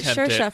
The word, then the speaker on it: Saoirse: It's spelled S-A-O-I-R-S-E. – Yeah, and I'm thinking, how in the world did you get Shersha from that?